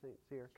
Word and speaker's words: St. Cyr: Jordan St. Cyr, okay. Yeah, he's sincere.